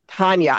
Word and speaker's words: Tania: Tania.